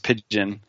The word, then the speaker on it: Pyjion: He came up with Pigeon, which is spelled P-Y-J-I-O-N. It throws everyone for a loop until Dean or I tell them it's pronounced Pigeon. At least that's how we expect people to pronounce it.